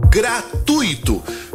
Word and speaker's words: gratuito: Gratuito. Qual é a forma correta de falar essa palavra? Gratuito ou gratuito? A forma correta é gratuito. Se fosse gratuito, nós teríamos um hiato ali.